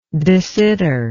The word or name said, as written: de Sitter